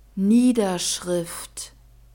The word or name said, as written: niederschrift